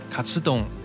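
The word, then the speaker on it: カツ丼: 因为炸猪排的日文发音卡吃懂，其中的卡吃就和胜利的发音一样，因此炸猪排被用作一种象征，暗示着士兵们的胜利和成功，使其成为一种具有象征性的战前食物。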